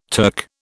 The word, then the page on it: Turk: British English